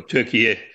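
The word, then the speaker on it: Türkiye: In fact, they regard themselves in Central Asia as being the parent of the Turkish people in Turkey, or Turkey, as it's pronounced in Turkish and how they want you to say it now.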